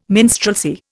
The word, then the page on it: minstrelsy: Indian